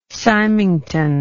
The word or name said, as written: symington